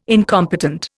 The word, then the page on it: incompetent: Indian